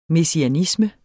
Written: messianisme

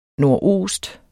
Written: nordost

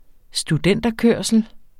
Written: studenterkørsel

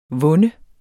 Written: vunde

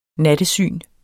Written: nattesyn

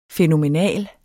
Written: fænomenal

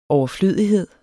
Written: overflødighed

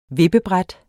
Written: vippebræt

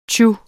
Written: tju

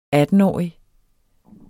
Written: attenårig

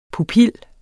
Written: pupil

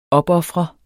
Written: opofrende

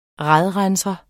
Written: RADRENSER

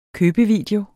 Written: købevideo

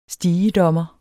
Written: stigedommer

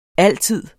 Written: altid

